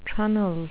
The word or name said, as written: trunnel